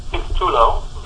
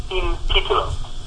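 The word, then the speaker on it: Sin Título: Well, I pronounce it incorrectly. I pronounce it Scene Tutulo, but that is technically incorrect. The Spanish speakers will tell you that it is Scene Tutulo.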